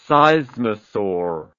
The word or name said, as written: seismosaur